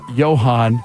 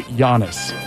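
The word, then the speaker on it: Johannes: It's J-O-H-A-N-N-E-S. One of them pronounces it Johan, and the other one pronounces it Giannis.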